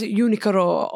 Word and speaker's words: Uniqlo: Like she'll be like, she says Unikuro, or she says, I love another Unikuro. One. But that's also because we studied Japanese, so we would kind of say it correctly.